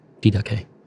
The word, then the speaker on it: Didache: That's how you say it, right? Didache? – Mm-hmm. It's an iota. – Oh, it is. Okay. Yeah. It's not Latin, huh? – No, it's Greek. Didache.